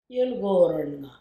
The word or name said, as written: Yilgoowoorroonnga